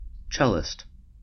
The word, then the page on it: cellist: American English